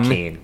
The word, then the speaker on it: Joaquin: and how do you pronounce the name? Joaquin Phoenix or Joaquin? – Joaquin. Yeah, it's Joaquin.